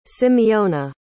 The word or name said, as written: simeona